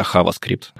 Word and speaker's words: JavaScript: прикольно, кстати, в Испании на метапах, когда они называют не JavaScript, а HavaScript. HavaScript.